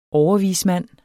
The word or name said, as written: overvismand